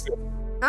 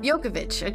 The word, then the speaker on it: Djokovic: He's a famous, Jokic. huh? – Jokic. Jokic. Djokovic. – Djokovic.